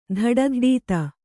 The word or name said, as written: ಧಡಧಡೀತ